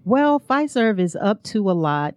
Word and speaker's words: Fiserv: We have Fiserv. Is that how you pronounce it? – Yes, Fiserv.